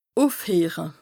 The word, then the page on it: ùfheere: Bas-Rhin; Strasbourg